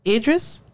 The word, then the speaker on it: Idris: How you pronounce his name? I don't want to disrespect. – We talk about the guy from England. – Yes. – Idris.